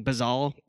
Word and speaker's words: bozal: The reference, I guess the start of it, essentially it's like a Spanish word, it's pronounced like bazal, B-O-Z-A-L.